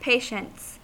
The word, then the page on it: patients: American English